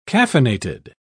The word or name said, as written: caffeinated